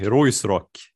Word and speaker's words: Ruisrock: Säger man Ruirock? Eller vad säger man? – Ruisrock? Nej, Roisrock.